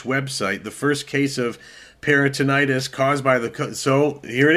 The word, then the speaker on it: peritonitis: The results of the inquiry so far indicate that Sorensen had abdominal peritonitis, which is the infection of the abdominal lining.